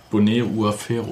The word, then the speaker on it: Boné Uaferro: Bonet Uafero.